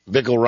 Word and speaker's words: Bickle: Bickle rhymes with tickle.